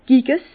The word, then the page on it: Giges: Bas Rhin; Herrlisheim